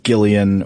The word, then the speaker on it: Gillian: How do you pronounce that name? – I think it depends because the way that I always heard Jillian Anderson, which I believe is how she pronounces it, but then the woman from Community, it's spelled the same. Hers is pronounced Gillian.